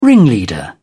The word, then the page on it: ringleader: British English